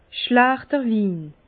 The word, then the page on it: schlächter Win: Bas Rhin; Herrlisheim